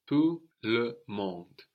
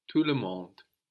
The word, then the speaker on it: tout le monde: Everybody in French, everybody, is tout le monde. Tout le monde. – Tout le monde. – So this is literally all the world. We have tout, T-O-U-T, silent T on the end, related to total, tout le monde.